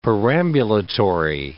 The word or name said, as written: perambulatory